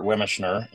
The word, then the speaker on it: Wemischner: And this is a wonderful human being known as Chef Bob or Robert Wemischner.